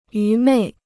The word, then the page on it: 愚昧: yú mèi